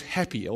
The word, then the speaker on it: Hapi: The God's name, or the God of the Nile, was Happy, or I don't know, my pronunciation of Egyptian gods is not that good, but it's spelled like that, H-A-P-I, that's what he looks like, and there he is.